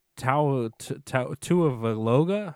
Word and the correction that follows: Tagovailoa: or whatever. I can't pronounce his last name. – Tua Valoga Tagovailoa. – Tagovailoa, that's what it is.